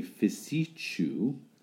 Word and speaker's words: Fiosaiche: A Fisichu, which is spelled... – Yes. – F-I-O-S-A-I-C-H-E.